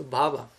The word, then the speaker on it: bhava: So bhava is material existence with short A, and from that we go to the long A with bhava.